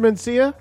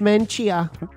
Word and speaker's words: Menchaca: Mr. Mencia? – It's Menchia. – It's spelled M-E-N-C-H-A-C-A.